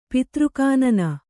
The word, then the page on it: ಪಿತೃಕಾನನ: kannada